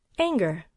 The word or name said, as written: anger